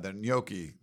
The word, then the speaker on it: gnocchi: I got the gnocchi in the like the red sauce. I like that. So that's you said it right. – Yeah, gnocchi.